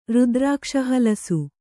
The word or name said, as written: ರುದ್ರಾಕ್ಷಹಲಸು